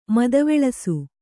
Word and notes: ಮದವಲಸೆ: madavalase

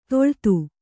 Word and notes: ತೊಳ್ತು: toḷtu

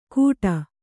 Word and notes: ಘೂಟ: kannada

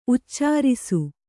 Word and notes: ಉಚ್ಚರಿಸು: uccarisu

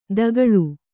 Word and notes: ತಗ್ಗಾಳು: taggāḷu

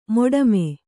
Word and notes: ಮಡಿಮೆ: kannada

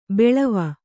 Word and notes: ಬಾಳುವೆ: Kannada

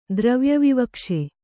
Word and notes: ದ್ರವ್ಯವಿವಕ್ಷೆ: Kannada

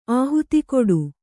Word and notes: ಆಹುತಿಕೊಡು: kannada

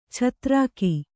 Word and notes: ಛತ್ರಾಕಿ: chatrāki

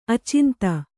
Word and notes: ಆಚಾಂತ: Kannada

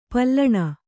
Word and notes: ಪಾಲುಣ: pāluṇa

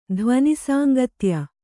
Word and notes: ಧ್ವನಿಸಾಂಗತ್ಯ: Kannada

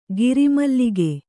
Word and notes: ಗಿರಿಮಲ್ಲಿಕೆ: giri mallike

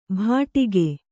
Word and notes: ಭಾಡಿಗೆ: Kannada

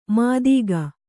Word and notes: ಮಾತಿಗ: kannada

